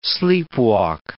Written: sleepwalk

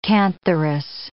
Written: cantharis

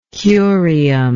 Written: curium

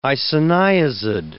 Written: isoniazid

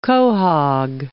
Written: quahaug

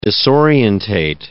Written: disorientate

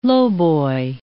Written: lowboy

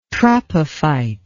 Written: tropophyte

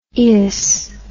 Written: ious

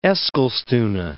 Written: Eskilstuna

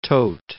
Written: Todt

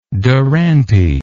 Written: duranty

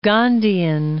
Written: Gandhian

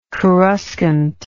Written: coruscant

